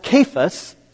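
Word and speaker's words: Kephas: There's also the word for rock, actual rock, I mean, and it's pronounced Cephas, spelled with a K, and it means rock.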